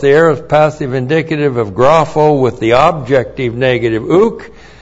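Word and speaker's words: Grapho (γράφω): And then we have grapho. G-R-A-P-H, long O. Grapho.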